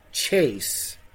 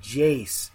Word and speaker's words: Jace: But he pronounced Jace Sternberger's name Chase. And I freaked out. I, like, stood up, and then he said Sternberger, and Adam, my brother, looks at me and just goes, Jace.